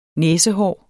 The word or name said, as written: næsehår